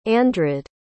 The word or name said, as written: andred